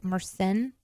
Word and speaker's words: Mersenne: I don't know how to pronounce this word. Mersenne. – Sennne? – Yeah, it's like Mersenne.